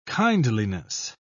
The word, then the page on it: kindliness: British English